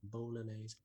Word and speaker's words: bolognese: You know, just so certain regions, apparently, if you go to and you ask for a bolognese, as I believe it's pronounced over in America, bolognese.